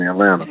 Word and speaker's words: Atlanta: One thing you got to know before you move to Atlanta is don't pronounce the second T in Atlanta. It's silent.